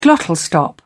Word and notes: glottal stop: British English

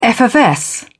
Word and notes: effervesce: British English

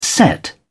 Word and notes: set out: British English

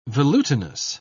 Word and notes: velutinous: British English

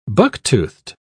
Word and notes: buck-toothed: British English

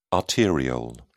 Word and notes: arteriole: British English

